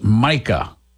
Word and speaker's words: mica: So I asked the question about, it's pronounced mica, turns out. I asked the question about this dust material that was on the folks on 9/11. D.R. Westbrook says it's pronounced mica.